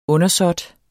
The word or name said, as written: undersåt